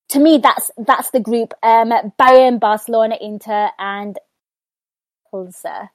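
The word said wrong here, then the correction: Plzeň: Sorry, I know I've said that wrong. – Victoria, please. – Polzen.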